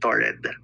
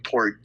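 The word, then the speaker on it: Taured: Mali nga yung pagkaka-pronounce ko dun sa episode eh. I said Torred. Dapat lang Tord.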